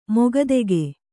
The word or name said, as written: ಮುಕ್ತಕ